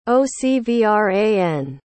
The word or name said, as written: ocvran